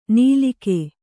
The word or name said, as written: ನೀಲಿಕೆ